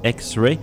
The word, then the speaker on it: Ex:Re: cette séquence de trois pièces se terminait sur la musique de X-Ray qui s'écrit E-X-R-E et qui se prononce X-Ray, donc qui est le projet solo d'Elena Tonra, qui est la chanteuse du groupe Daughter.